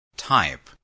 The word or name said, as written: type